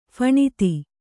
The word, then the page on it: ಫಣಿತಿ: phaṇiti